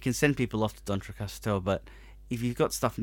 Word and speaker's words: D'Entrecasteaux: D'Entrecasteaux.